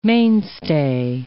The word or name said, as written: mainstay